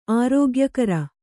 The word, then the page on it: ಆರೋಗ್ಯಕರ: ārōgyakara